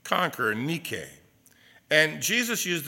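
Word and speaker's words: nike (νίκη): And the Greek word for overcomer is nike, or the way we like to pronounce it, Nike.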